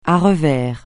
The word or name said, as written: à revers